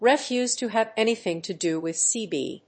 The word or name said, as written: refuse to have anything to do with sb